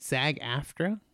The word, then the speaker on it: SAG-AFTRA: Is it SAG-AFTRA? – Yeah.